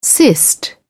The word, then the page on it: cyst: British English